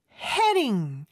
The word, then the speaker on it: heading: 자, 일단 I'm 발음으로 해준 다음에 I'm heading. D 발음은 여기 모음 소리 사이에 껴있기 때문에 리을처럼 발음이 돼요.